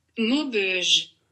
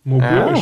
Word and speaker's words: Maubeuge: ich glaube, es wird Maubeuge ausgesprochen. Ich äh, hol mir mal Hilfe kurz. – Maubeuge. – Ja, Maubeuge.